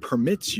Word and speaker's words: permit: Permit as a verb and permit as a noun are pronounced differently.